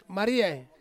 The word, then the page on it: mariai: Ansus